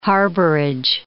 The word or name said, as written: harborage